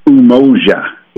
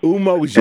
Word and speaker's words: Umoja: Well, let me spell it first. It's UMOJA, and it's pronounced Umoja. – Umoja. – And that's, yeah, Umoja. And just like, ooh, ooh, good, right? – Yeah, yes, sir, yes, sir.